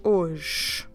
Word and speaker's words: hoje: We do H-O-G-E, H-O-J-E, hoje. The H in the beginning is silent, and we also drop the E at at the end. So H in the beginning is silent, E at the end is also silent. So the only thing you get is the sound hoje.